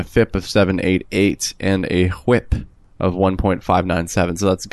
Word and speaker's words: WHIP: Nobody says WHIP. – WHIP. I believe that's how it's pronounced.